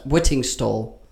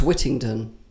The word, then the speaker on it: Whittingstall: You're saying his name wrong, aren't you? Is that right? Whittingstall. I thought it was Whittington. No. – No, that's Dick Whittington.